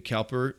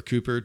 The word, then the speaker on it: Cowper: I think John Newton's relationship with William Cowper, or Cooper, depending on how you say it, is really an encouraging study.